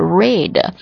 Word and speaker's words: read: 到目前为止 I have read the book, 注意一下 read 这个单词它的过去式和过去分词呢，读音读作 read, I have read the book。